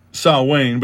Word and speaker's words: Samhain: It's pronounced Samhain, but it's spelled S-A-M-H-I-I-N. It's a pagan festival.